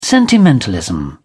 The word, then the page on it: sentimentalism: British English